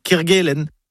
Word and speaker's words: Kerguelen: Kerguelen. On connaît tous les îles Kerguelen. Alors, pourquoi on entend parfois parler de Quai Kerguelen, comme à Quimper, par exemple? Non, c'est Quai Kerguelen.